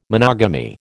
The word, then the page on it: monogamy: American English